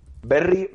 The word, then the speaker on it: Berri: Berry, B-E-R-R-I. – Sí, Berry sí. Eh, Charrak, T-X-A-R-R-A-K.